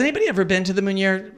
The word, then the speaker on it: Meunier: And everybody doesn't know how to pronounce Meunier Chocolate Factory because it looks like Meunier. But it's Meunier.